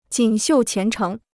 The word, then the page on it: 锦绣前程: jǐn xiù qián chéng